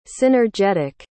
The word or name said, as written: synergetic